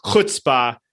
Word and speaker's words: chutzpah: It is a chutzpah. No, you got to get totally be like an old Jewish man with the...